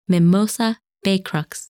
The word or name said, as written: Mimosa, Becrux